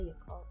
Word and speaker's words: Yakult: With me, I don't know if it's like Yakult or Yakult, but I usually say Yakult. You know what I'm talking about? – You're saying it right.